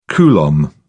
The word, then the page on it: coulomb: British English